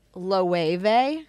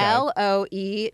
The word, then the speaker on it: Loewe: I think the brand is pronounced Loewe. – Okay. – Loewe.